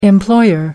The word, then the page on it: employer: American English